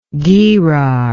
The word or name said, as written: gerar